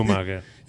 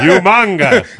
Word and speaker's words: Umaga: Sorry, Umaga. – Nah, you don't have to let it go. – Thank you. – That's how Estrada would pronounce it. – Yeah, Umanga.